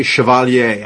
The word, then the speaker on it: Chevalier: And I pronounce it the French way, Chevalier. I don't know if it's Chevalier, like the way, you know, but think about Chevrolet.